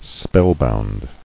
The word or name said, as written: Spellbound